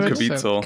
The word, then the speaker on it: Caviezel: Jim Caviezel.